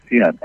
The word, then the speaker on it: Xi'an: No, X-I-A-N-G. – Oh, what? Xi'an, like Xi'an. X-I-A-N is Xi'an.